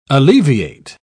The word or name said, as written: alleviate